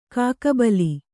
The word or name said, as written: ಕಾಕುಬಲ